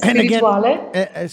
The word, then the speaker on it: spirituale: So, Papalo Spirituale, is that right? Spirituale? – Yes, Spirituale.